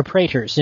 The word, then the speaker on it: praetor: For the listeners, you use manuscripts to train your praetors in new skills.